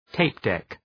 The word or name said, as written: tape deck